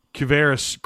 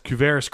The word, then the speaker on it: Quavaris: I think it's Kavaris Crouch. It's spelled Q-U-A-V-A-R-I-S. Kavaris Crouch and offensive lineman, Jameer Johnson.